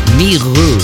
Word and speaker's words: Mirau: Después del Coma Cat Something About You aparecerá a través del sello Miru Se escribe Mirau, pero se pronuncia en francés.